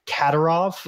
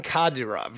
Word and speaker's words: Kadyrov: I believe it's pronounced Kadyrov. – Ramzan Kadyrov, yes.